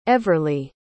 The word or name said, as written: everlee